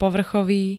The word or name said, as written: povrchový